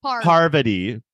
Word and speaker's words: Parvati: It was, I literally like, okay, John to me was literally the most clocked in except for like Parvati. Am I saying it right now? Because now I'm like, I'm not. Okay. – Parvati. Yes, perfect.